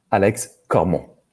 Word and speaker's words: Alex Cormont: Alex Cormont.